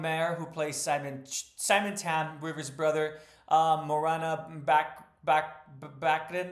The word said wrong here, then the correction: Baccarin: excuse me, I mispronounced that as well, Baccarin, and Nora.